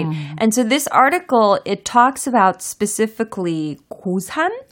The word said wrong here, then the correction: 괴산: 아 괴산.